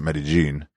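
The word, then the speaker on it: Medellín: And in case you're wondering, why I pronounce it Medellin, when you get there, the double L in Spanish is either a Y in some places or a J in Colombia, at least. It's like a J.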